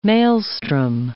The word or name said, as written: maelstrom